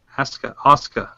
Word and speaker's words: Asuka: Asuka. – I'm sorry. I must be William Regal or something. But anyways... – At least you didn't say Asuka.